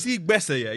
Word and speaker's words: Gbese: The reason why that's funny, you see Gbese, you know Burna Boy does that song. Gbese. Yeah, buddy. Yeah. It's spelled G-B-E-S-E.